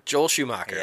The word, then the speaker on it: Joel Schumacher: Joel Schumacher.